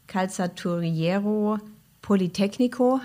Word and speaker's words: Calzaturiero Politecnico: Also es ist ja schon eine langjährige Zusammenarbeit zwischen der BASF in Italien und der Universität in Padua, Calzaturiero Politecnico heißt sie, glaube ich, wenn ich es ungefähr richtig ausgesprochen habe. – Gutes Italienisch.